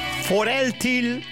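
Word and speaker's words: For altid: Foreltil. Ja, dat schrijf je met een D op het eind. Maar dan spreek je in Deens uit als een L.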